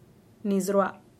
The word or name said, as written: niis rua